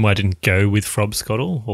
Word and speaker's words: frobscottle: Or frobscottle, nothing but a whiz-popping great time. Keith? – It's Queen's breakfast but approaching frobscottle. Did I say that right? – You did.